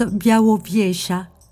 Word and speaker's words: Białowieża: Ça s'écrit Bialoviesa, ça se prononce Białowieża, dernière grande forêt primaire d'Europe, il n'en reste que des lambeaux ailleurs, en Roumanie notamment.